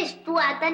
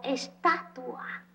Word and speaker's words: estatua: estuata. – No se dice estuata, se dice menumento. – Pero qué mal están los dos. Ni se dice estuata, ni se dice menumento. Se dice monumento o estatua.